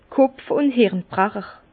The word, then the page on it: Kopf ùn Hirn Brecher: Bas Rhin; Herrlisheim